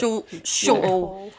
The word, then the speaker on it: Shortall: Yeah, there's no H. But, Shortall.